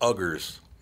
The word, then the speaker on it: Uyghur: So the Muslims in China are called, it's spelled U-I or U-Y-G-H-U-R-S. Okay? U-I-G-H-U-R-S. You know how it's pronounced? – No. – Uyghur. – Oh. Okay. – You don't want to be pronouncing it like that mm-hmm.